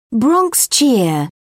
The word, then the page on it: bronx cheer: British English